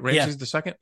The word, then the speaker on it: Ramses II: Ramses II? – Yep.